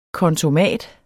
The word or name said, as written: kontomat